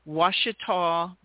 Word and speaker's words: Ouachita: He is working on a marketing and finance major at, I'm going to try to say this right now, Mason, it's Ouachita Baptist University.